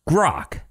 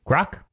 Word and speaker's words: GROQ: It's called Grok. I believe that's how you pronounce it. Grok? G-R-O-Q? Grok?